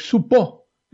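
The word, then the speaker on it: Csupo: así difícil de pronunciarlo, tanto uno como otro, que es una compañía de animación fundada en 1982 por Vivian Claxi y Gabor Supo, ¿no? con acento en la O, aunque bueno, el nombre de la empresa no lleva acento en la O, que eran un matrimonio, ¿no?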